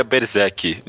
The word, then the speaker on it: Berserk: Eu Porque todo mundo fala Berserker. – Mas é, não tem air no final.